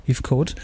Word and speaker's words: Heathcote: Dann haben wir Jenna Malone, Bella Heathcote, spricht man das richtig aus? Heathcote. Genau, Christina Hendricks und Keanu Reeves in einer, wen haben wir? – Abby Lee Kershaw, die Sarah spielt.